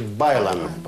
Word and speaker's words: bailan: Видите окончание «ан», а ударение на предпоследний слог, значит «байлан».